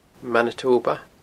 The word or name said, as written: Manitoba